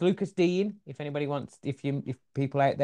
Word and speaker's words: Lucas Digne: I tweeted it out, because there's been quite a few ways, and it's Lucas Digne. Lucas Digne is the way we say it. That's the way he pronounced it.